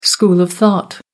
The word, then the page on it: school of thought: American English